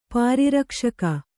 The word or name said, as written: ಪರಿರಕ್ಷಕ